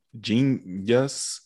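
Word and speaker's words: jeen-yuhs: And today we're going to talk about the Kanye West documentary, which I pronounced incorrectly on our first go round here. And that's Genius. I guess it's just Genius.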